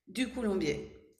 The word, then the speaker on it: Ducoulombier: Ducoulombier.